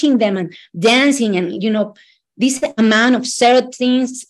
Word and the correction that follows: serotonin: uh, eh, serotins is how you say uh, serotonin.